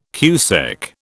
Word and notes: cusec: British English

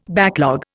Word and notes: backlog: Indian

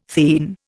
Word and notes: theine: Indian

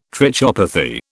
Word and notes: trichopathy: British English

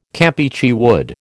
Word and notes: Campeachy wood: American English

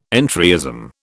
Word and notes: entryism: British English

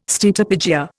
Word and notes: steatopygia: Indian

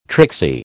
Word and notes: tricksy: American English

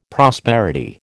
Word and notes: prosperity: American English

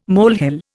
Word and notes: molehill: Indian

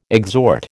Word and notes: exhort: American English

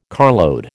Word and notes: carload: American English

